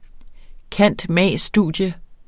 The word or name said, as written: cand.mag.